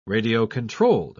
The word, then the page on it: radio-controlled: British English